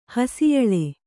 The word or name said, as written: ಹಸಿಯಲ್ಲ